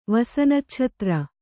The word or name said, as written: ವಸನಚ್ಛತ್ರ